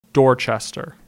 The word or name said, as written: Dorchester